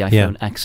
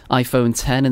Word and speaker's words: iPhone X: Because obviously Apple launched, what, three phones just a few months ago, but then you had one, the iPhone 10 or the iPhone X, depending on how you say it. I'm sure you'll correct me on that one in a minute. – It's 10.